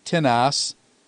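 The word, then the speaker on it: tinas (τίνος): In the masculine feminine, the nominative is tis, genitive is tinas, dative is tinny, and accusative is tina. You'll notice that the accent falls on the first syllable when there are two syllables, as opposed to the indefinite pronoun, which has it on the second syllable, on the ultima.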